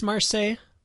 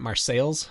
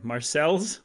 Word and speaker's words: Marseilles: You would think it's Marseille, but the Illinoisans pronounce it Marseilles. – Okay, Marseilles.